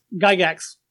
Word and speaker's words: Gygax: Gygax.